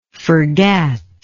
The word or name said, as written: forgat